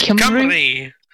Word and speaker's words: Cymru: Racing up to third place, the fabled Rincewind Himru. Did I pronounce that right? Hold Cymru. second spot. Rincewind Cymru. – It's Cymru. Cymru. Okay.